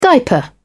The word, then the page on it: diaper: British English